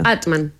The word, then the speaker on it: Amtmann: Yo hago como que la M no existe entre medio. Amtmann. – Pronuncia tu apellido. – Amtmann.